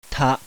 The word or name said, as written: TA